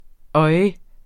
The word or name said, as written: oj